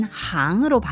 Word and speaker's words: 行: 그리고 저항 해서 이 항이 보통 싱으로 쓰일 때가 많은데 여기서는 싱이라는 발음 대신 항으로 바뀌면서 직업 그리고 업, 업무로 해석되어진다.